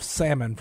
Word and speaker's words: salmon: So it is salmon. The L is silent. – Yeah, no L.